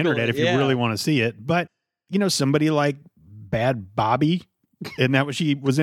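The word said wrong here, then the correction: Bhad Bhabie: Bad Baby.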